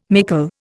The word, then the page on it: mickle: Indian